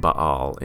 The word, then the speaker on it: Baal: Pronounced Baal in Hebrew, but we say Baal because we don't know what to do with two A's together generally.